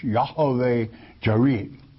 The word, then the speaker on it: Yahweh Jireh: I have to pronounce this from the Hebrew manuscripts, not as it is written in English. Yahweh Jireh.